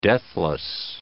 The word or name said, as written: deathless